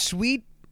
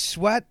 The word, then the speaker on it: Sweat: It's not pronounced Sweet. It's Sweat.